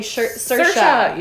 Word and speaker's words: Saoirse: Is actually Saoirse.